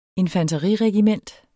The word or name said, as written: infanteriregiment